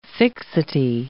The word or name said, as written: fixity